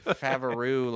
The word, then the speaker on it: Favreau: Favreau, I believe. – Favreau, like a kangaroo.